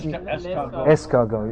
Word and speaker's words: escargot: And l'escargot, is it? Did I say it right? – Escargot.